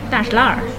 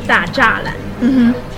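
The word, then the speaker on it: 大栅栏: If you know Chinese, you made it Da Zha Lan. But local Beijingers will pronounce it like Da Shi La. Da Shi La. If it's standard 普通话, it's Da Zha Lan. But local people will just say Da Shi La.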